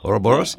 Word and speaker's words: Ouroboros: the Ouroboros. Did I say that right? – Ouroboros. Yeah, right.